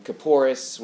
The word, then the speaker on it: Kapores: And Kapores, which is the Ashkenazi pronunciation of Kapporet, of atonement.